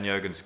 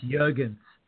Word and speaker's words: Jurgens: Funny how you pronounce Jurgens. – You know what? I have found that's a UK thing, because I have a good friend who is in the north of England, and he pronounces it Jurgens as well. So I'm thinking it's just kind of a regional thing from there. They just choose to make the J silent.